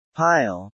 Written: pile

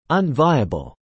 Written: unviable

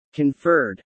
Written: conferred

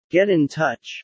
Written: get in touch

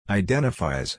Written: identifies

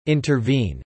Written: intervene

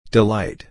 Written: delight

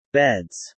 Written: beds

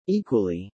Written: equally

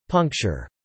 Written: puncture

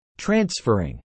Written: Transferring

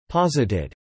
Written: posited

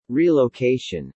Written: relocation

